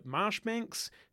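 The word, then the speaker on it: Marjoribanks: Another one is Alexander Marshbanks, who in the episode I called Majori Banks, because I didn't realize that you actually pronounce it Marshbanks, which is weird because it's not spelt like that.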